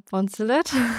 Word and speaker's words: Bonzelet: Äh, ich freue mich sehr, heute meinen Gast begrüßen zu dürfen, den Christian Bonzelet.